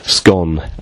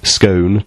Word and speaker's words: Scone: A gentleman named Toot, I don't know if that's his real name, said, some say scone to rhyme with own, Most say scone to rhyme with gone. However, the stone of Scone, the old seat for the King of Scotland, rhymes with coon, and I thought that was brilliant.